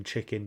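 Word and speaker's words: Chickyn: And then chicken became a thing on the black market. – I pronounce that as chicken.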